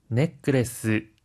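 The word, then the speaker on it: ネックレス: 하이, 넥크레스, 브레스레트라고 할 때는 중간에 초금이 들어가는데요. 한 박자 쉬었다가 발음하는 점 주의해 주세요.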